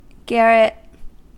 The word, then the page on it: garret: American English